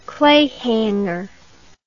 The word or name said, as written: clayhanger